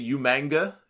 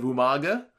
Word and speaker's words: Umaga: Uh, like uh, kind of the way he used to say Umanga instead of Umaga, because he just butchered this name when he said it.